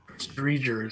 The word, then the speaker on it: Driedger: Dreger is how it's pronounced.